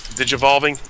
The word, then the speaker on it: Digivolving: Digivolving. Did I say that right? Yeah.